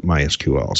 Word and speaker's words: MySQL: And the other thing we have is MySQL based, I think you call it MySQL. – it could be pronounced either way. You know, it's the strange thing about the internet. We all read all of these terms and come up with our own pronunciations of it. I've gotten some very strange looks over the years. – Well, I've heard directly from Monty himself. He calls it MySQL. So that's the authoritative version.